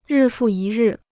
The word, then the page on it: 日复一日: rì fù yī rì